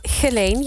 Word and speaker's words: Geleen: Hoe zeg je dat goed? Jij komt uit die streken. – Geleen, je deed het helemaal goed. – Ik deed het goed. – Ja. Geleen.